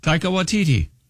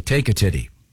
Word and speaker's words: Taika Waititi: Taika Waititi. – Okay. I thought it was like Take a Titty.